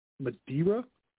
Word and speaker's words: Madeira: Uh, after multiple batches are blended, they're aged in a total of 86 different barrels that previously held, uh, it's pronounced uh, Madeira, port, and sherry.